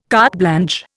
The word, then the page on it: carte blanche: Indian